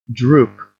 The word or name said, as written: droop